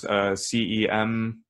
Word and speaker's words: Cem Yılmaz: And that's pronounced Cemyilmaz.